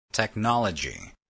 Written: technology

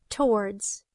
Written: towards

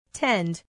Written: tend

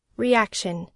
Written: reaction

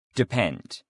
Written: depend

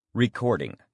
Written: recording

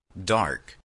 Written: dark

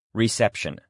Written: reception

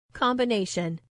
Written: combination

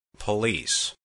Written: police

